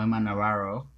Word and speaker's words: Navarro: La próxima del ranking tenemos a Emma Navarro, o Emma Navarro, así se le pronuncia en inglés, porque es americana, entonces hay que pronunciarlo con el acento americano.